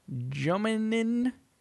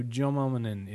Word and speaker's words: J0M1N1N: So I'm just going to point out for people how it is spelt. J0M1N1N. So Jominin isn't bad.